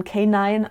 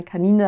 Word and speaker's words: Canine: Eigentlich wird es englisch ausgesprochen K9, aber ich sage immer gerne Canine, damit die Leute auch wissen, wie es geschrieben wird.